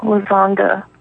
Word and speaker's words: Lazonga: I have, well, I guess Vivine Lozonga. That's the easiest one. And it's spelled V-Y-V-Y-N. And then my last name is L-A-Z-O-N-G-A.